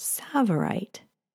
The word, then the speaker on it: tsavorite: This garnet happens to be green and it's called savorite. It's spelled T-S-A-V-O-R-I-T-E, and it was first discovered in the Tsavo National Park in Kenya in the late 60s.